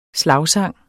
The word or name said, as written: slagsang